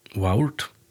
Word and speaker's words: waurd: Och stort lite är att agotiskon verda Waurd, W-A-U-R-D, men A-U uttala som O, så her verda Waurd.